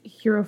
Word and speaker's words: Hiro: It's Working Class Hero, but it's spelled H-I-R-O. – Like Hero from Heroes? – Yes, exactly.